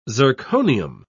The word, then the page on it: zirconium: British English